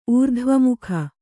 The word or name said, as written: ಊರ್ಧ್ವಮುಖ